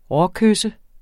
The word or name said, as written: råkysse